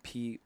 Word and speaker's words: Porangui: Yeah, so Bandcamp, just a Porangi, my name, Porangui, accent on the I, but you don't need that searching.